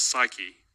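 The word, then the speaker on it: psyche (ψυχή): In the Greek, the word for life in verse 35 is psuche, or as we would pronounce it, psyche.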